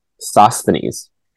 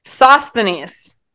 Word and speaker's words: Sosthenes: Sosthenes. – Sosthenes. Why is that so hard? It's that extra S in there.